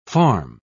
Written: farm

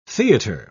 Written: theater

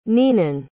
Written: neenan

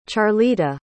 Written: charlita

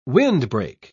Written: windbreak

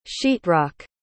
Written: sheetrock

